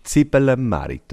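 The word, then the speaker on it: Zibelemärit: Habe ich es richtig ausgesprochen? Zibbelmerit, oder? – Ja, einfach. Ja, das Weh weglassen.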